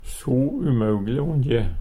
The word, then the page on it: umaugle: Numedalsmål